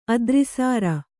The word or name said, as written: ಅದ್ರಿಸಾರ